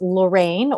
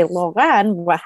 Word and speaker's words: Lorran: Once again, my name is spelled L-O-R-R-A-N, but it's pronounced Lorraine, or if you are French, you could say Lorraine, how it's spelled, and the last name is Garrison.